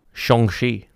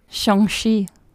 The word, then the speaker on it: Shang-Chi: But as uh, Shang-Chi? – Shang-Chi. – Shang-Chi.